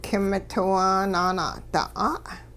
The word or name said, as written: k'imitiwaa naana'da'a'